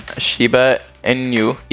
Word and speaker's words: Shiba Inu: Okay, he drives a Shiba Inu. – What that is? – That's a Doge. Hold on, let me make sure I'm saying that right. – Okay then. – I'm a nerd. Shiba Inu. Yep.